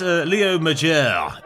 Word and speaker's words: Major: Well, he is Leo Majeure, is French-Canadian, so it's spelled Leo Major. – Okay, good. – But there's a kind of a little hat on the E. Right. There isn't even an E in his name, actually. There's a little hat somewhere. It's pronounced Frenchly.